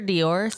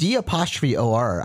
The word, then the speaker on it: d'or: And it's gotta be Dior, kind of, right? And so no, that's actually what that means. – D'OR. I feel like I pronounced it correctly.